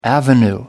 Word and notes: avenue: American English

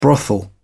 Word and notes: brothel: British English

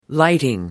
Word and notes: lighting: American English

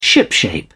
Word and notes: shipshape: British English

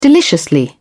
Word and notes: deliciously: British English

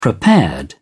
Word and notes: prepared: British English

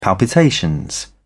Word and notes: palpitation: British English

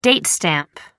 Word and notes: date stamp: American English

